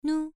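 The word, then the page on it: nu: tone 1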